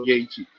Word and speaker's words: Yaeji: 五五六年前吧，在纽约的 DJ 圈突然很红的一个韩国籍的女 DJ，她叫做 Yaeji Y A E J I，然后这是她的韩文名字的，直接用英文来发音这样。